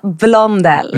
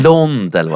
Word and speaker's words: Blondell: Nanna Bloell. Nu är det namnhaveri igen. – Det är mycket enklare. Nanna Blondell. – Blondell.